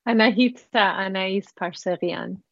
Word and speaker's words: Anahita Anais Parseghian: Anahita Anais Parseghian.